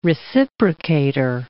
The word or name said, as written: reciprocator